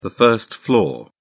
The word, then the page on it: first floor: British English